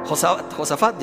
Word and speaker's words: Josafat: Le habla al rey Jocabat. Josafat, ya le cambié el nombre.